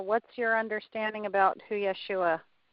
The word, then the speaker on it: Yeshua: It's pronounced Yeshua, Yeshua, something along those lines.